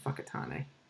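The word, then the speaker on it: Whakatāne: Just it's actually spelled Whakatane, but I think it's the Maori pronunciation, Fakatane. Not that that's Maori or a good pronunciation, but that's how they say it.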